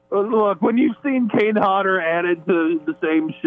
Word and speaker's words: Hodder: You going, I mean, seriously, how can people get so excited to meet Kane Hodder at, how do you pronounce it, Hodder? – Hodder. – Hodder, yeah. There we go, that too, Hodder, cold.